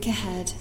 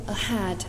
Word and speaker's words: ahead: To look ahead To look ahead，连读啊。然后这里要注意是 ahead 这个词，你不要读成了 ahead， ahead 不对，是 a 小 a， look ahead， ahead。